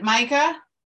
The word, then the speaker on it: Micah: Is it Micah? – Micah. – Yeah.